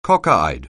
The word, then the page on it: cockeyed: anglais